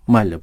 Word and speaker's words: Μάλια: Με δύο λάμδα ή ένα λάμδα. Δεν έχει σημασία, Μάλια προφέρεται.